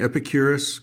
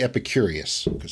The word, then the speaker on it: Epicurus: Yeah, in your chapter, am I pronouncing Epicurus correctly? – Is that yes, but don't say Epicurious.